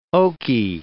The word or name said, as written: Okie